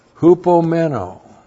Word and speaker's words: hupomeno: hupomeno. H-U-P-O. That's under. And meno. M-E-N, long O.